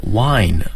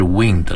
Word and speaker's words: wind: 首先大家要注意哈，这个发音的这个正确性，对，它的元音是 ein wind，而不是 wind 了。